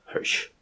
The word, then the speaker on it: Hirsch: H-I-R-S-C-H? – Probably Hirsch. – Hirsch? – Hirsch. It's definitely Hirsch. It's also not that hard to pronounce.